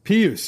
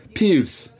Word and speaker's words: Pius: Pius Suter. – Pius. – Yeah, I butchered Pius.